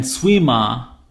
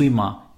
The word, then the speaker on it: Suima: Anyways, I fell asleep, and Suima, that's the way you pronounce it. Suima, it's like swimmer, like Aqua said.